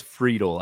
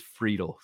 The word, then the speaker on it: Friedel: If you have an outfield, and it is Friedel. I did pull up the official MLB pronunciation guide. – Thank you, Steve Gardner. – Yep. Who shared that with us. It is Friedel, because I know I think we'll be saying his name quite a bit this year.